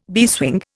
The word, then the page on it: beeswing: Indian